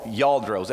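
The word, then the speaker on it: Lladró: It starts with an L, but it sounds like a Y or G or something. But it's a yaldro, is what I've been told is how you pronounce them.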